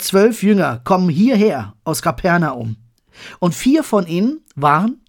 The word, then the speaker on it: Kapernaum: Nein, Kapernaum. Jesus zieht nach Kapernaum. Anders ausgesprochen, Kapharnaum. Es heißt übersetzt das Dorf von Nahum, Kapharnahum im Original.